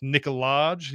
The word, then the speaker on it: Nikolaj: Apparently is it pronounced Nikolaj? You have to you do the J and everything. I don't know. – I do. – yeah, you do the J.